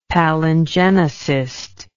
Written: palingenesist

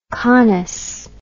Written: conus

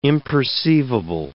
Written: imperceivable

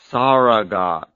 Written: saragat

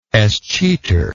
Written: escheator

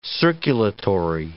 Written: circulatory

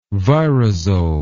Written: virazole

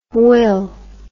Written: hwyl

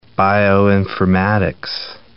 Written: bioinformatics

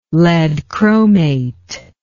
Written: lead chromate

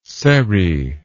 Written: seri

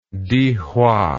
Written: dihua

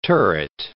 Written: turret

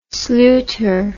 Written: sluter

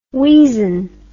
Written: weazen

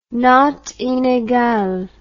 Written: notes inegales

